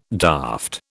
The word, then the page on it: daft: British English